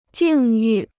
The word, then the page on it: 境遇: jìng yù